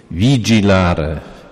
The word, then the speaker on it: vigilare: Włosi jak wymawiają to słowo, to wymawiają nawet miękko. Vigilare.